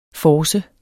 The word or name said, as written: force